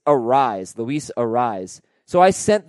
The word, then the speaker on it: Arias: He pronounces it Arise, no S sound on the end, like Luis Arias.